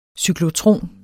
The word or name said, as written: CYKLOTRON